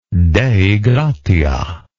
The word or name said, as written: dei gratia